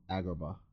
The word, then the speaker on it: Agrabah: Name the place again, because I will always say it wrong. – Agrabah.